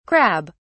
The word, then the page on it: crab (s): British English